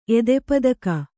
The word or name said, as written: ಎದೆಪದಕ